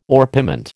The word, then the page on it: orpiment: American English